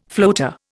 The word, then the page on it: floater: Indian